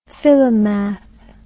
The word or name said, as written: philomath